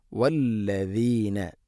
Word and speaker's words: والذين: വല്ലദീന ദാലിൻ്റെ ഉച്ചാരണം നമ്മൾ ശ്രദ്ധിക്കണം, ധാരാളമായിട്ട് കേട്ടിട്ടുണ്ടത്. എന്നിരുന്നാലും വല്ലദീന എന്നോ അല്ല. വല്ലദീന നാവിൻ്റെ അറ്റം മുൻപല്ലുകളുടെ ഇരുനിരകൾക്കിടയിലൂടെ അല്പം പുറത്തോട്ട് നിൽക്കണം.